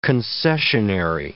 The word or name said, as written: concessionary